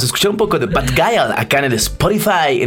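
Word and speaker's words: Bad Gyal: ¿Cómo se dice? ¿Bad Guile? – Bad Guile.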